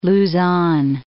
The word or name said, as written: Luzon